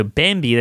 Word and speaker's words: Bambee: So Bambi, that's spelled B-A-M-B-E-E, was created specifically for your small business.